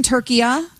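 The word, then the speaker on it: Türkiye: So you're saying, honey, you're saying that in that language, it's always been turkiya? – That's what he's saying, yes.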